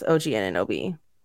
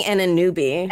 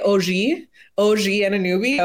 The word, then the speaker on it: Anunoby: So, yeah, I kept saying Anunoby. It was really awkward. – Like saying it like en français, OG Anunoby.